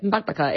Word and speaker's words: Bakbaka: There's another recipe, and boy, I'm not confident that I will pronounce this correctly, but it's the one pan Bakbaka. You did well. That's it. Bakbaka. It's an unusual word.